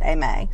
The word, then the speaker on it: Aimee: I, every girl I've ever known that spelled it A I M E E pronounced it Ame.